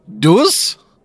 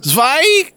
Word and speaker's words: zwei: Deuce? Is that how you say it in German? – Zwei.